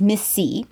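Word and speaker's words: MIS-C: And you may have heard of a new condition that's been labeled MISC, pronounced Miss C, or multisystem inflammatory syndrome in children.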